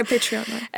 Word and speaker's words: Patreon: Là, je sais pas, je vais bien le prononcer, là, Patreon. – Ouais, Patreon.